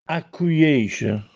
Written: Accuièsce

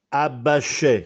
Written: Abbascè

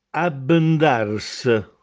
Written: Abbendàrse

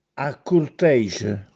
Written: Accurtèsce

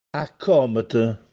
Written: Accòmete

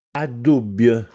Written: Addùbbj

